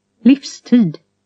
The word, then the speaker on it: livstid: V is pronounced F. – Livstid.